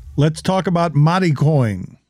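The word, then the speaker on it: Mari Coin: M-A-R-I coin. Mati coin. I'm not making it maritime. I'm pronouncing it Hispanically. – Yeah, well, be careful with that, especially. They're going for something. – Mati coin?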